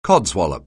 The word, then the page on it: codswallop: anglais